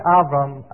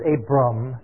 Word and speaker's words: Avram: If I'm saying Avram, I'm not mispronouncing it. I know it's Abram. But in Hebrew, the V, this letter, is a V sound. When you're saying it, it's Avraham, Avram. And because I talk to Jewish people all the time, I just automatically say that.